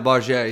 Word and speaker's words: Bargère: Yeah, Barger. Yeah, it's French pronunciation.